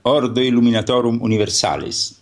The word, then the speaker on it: Ordo Illuminatorum Universalis: It's Latin, so it needs a pronunciation which is like Ordo Illuminatorum Universalis.